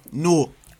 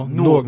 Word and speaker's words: Newark: Newark. – No, Newark. Not Newark. – Newark. – He's from New York. He don't know how to say it. I'm from Newark. It's Newark and then it's Newark.